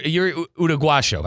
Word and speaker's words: Uruguayo: You know, you're Uruguayo. I said that right. – Yep.